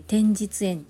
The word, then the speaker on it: 天日塩: 天日塩って言って天のお日様の日の塩って書いて天日塩って読むん